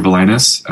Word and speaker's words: Valaimis: My name is Andrew Villanis. My last name is spelled V-A-L-A-I-M-I-S, zooming in from Missoula, Montana.